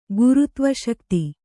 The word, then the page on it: ಗುರುತ್ವಶಕ್ತಿ: gurutva śakti